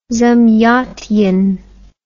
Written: zamyatin

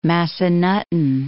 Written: Massanutten Mountain

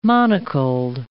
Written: monocled

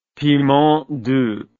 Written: piment doux

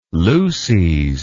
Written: luces

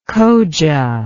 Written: khoja

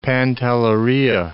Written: Pantelleria

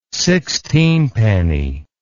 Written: sixteenpenny

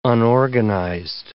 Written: unorganized

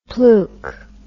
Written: pluke

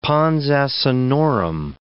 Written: pons asinorum

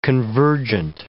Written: convergent

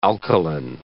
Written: alkaline

